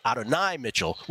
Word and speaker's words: Adonai Mitchell: Then people say Adonai Mitchell. And then, of course, those on the leaflet and those in the Mishpucha, we say Adonai Mitchell, which I think is how you really should pronounce it.